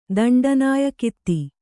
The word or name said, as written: ದಂಡನಾಯಕಿತಿ